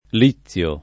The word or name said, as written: Lizio